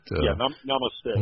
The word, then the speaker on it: Namaste: You have this new album, Namaste, and I've got Namaste. – Namaste. Yeah. So you know what? I always put the emphasis on the wrong syllable.